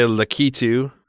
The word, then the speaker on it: Lakitu: Is it Lakitu or Lakitu? – Lakitu. Some people say Lakitu.